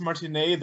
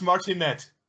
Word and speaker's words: Martinet: I remember prior to discussing interviewing Charles Martinet that I was discussing with AJ. I'm pretty sure it's pronounced Charles Martinet. And I'm like, no, he is French-Canadian. – Yeah, it's Martinet.